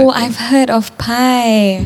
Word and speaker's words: Pai: I went to Pai. – Pai? – Yeah, Pai is a city just a bit north. – The life of Pai? – Yeah, that Pai, like it's pronounced that way.